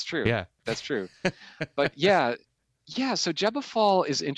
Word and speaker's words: Gebofal: I take the pronunciation from Aaron Leach, which is Gebefall. And if somebody knows the pronunciation, Gebefall.